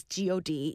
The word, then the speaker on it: g.o.d.: in general, for those of you who are new and you see this group, they're not read as God. They're read as G.O.D., even though there's no periods in between the letters. It is pronounced G.O.D. You spell out the group name, just for everybody who didn't know.